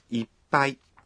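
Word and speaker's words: Ippai (いっぱい): Ipai n'est pas facile à prononcer dans on retrouve un son étouffé. Quand vous le prononcez, vous devez suspendre votre souffle pendant un temps. À l'écrit, on utilise tsu pour épeler un son étouffé. Exerçons-nous à le prononcer. Ippai.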